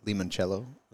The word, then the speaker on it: limoncello: Limoncello. – Limoncello.